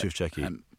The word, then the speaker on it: Tufekci: Her name was Zeynep Tufekci, I think, if I said her name right. – I think Tufekci, but Tufekci. – It's been a while, so. Tufekci? – Yeah.